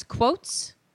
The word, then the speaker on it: quoits: Now, how the heck you pronounce this is beyond me. It's Q-U-O-I-T-S. Quoits. – Quoits. Quoits is what they call a horseshoe sometimes, too.